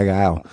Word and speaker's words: Guile: No, my name is Guy Guile. – Guy Guile. – They'd be calling me Guy Gale. Guy Gale. Guy Gale. No, it rhymes with my first name, fuckers. And he'd spell it G-E-I.